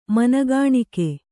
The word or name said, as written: ಮನಗಾಣಿಕೆ